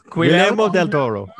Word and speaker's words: Guillermo del Toro: There you go. This is why we brought you guys in. It's just you're like, can you pronounce things? Yes, you're on. – We know nothing about horror movies, but we can pronounce Guillermo.